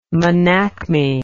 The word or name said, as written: menacme